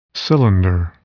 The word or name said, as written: cylinder